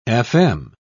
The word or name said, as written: fm